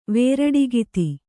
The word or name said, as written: ವೇರಡಗಿತ್ತಿ